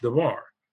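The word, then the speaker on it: devar (דבר): We would bring it into English as D, as in dog, D-A-B-A-R, pronounced devar.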